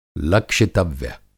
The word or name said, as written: लक्षितव्य